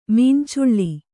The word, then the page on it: ಮೀನ್ಚುಳ್ಳಿ: mīncuḷḷi